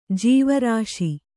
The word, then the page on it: ಜೀವರಸ: kannada